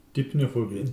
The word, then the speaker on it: Deipnophobia: Deipnophobia. – Deipnophobia. – D-E-I-P-N-O phobia.